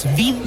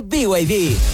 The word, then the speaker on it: BYD: ¿Los VID? – ¿VID? ¿No es VID? – Nada que ver, se escribe BYD, pero se dice BYD, la marca número 1 de electromovilidad en Chile y el mundo.